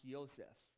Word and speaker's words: Yosef: And in Hebrew, his name is pronounced Yosef in Hebrew.